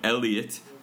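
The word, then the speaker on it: Elliott: 지금 마이클 엘리엇 ELLIOTT로 바꿔서 예. 그동안 EiK 써왔잖아요. 찾기 쉽지만 발음하기 어려운 EiK로 하고 예. 있었지. – L 두 개고 T 두 개입니다. Double 맞아. L, Double T.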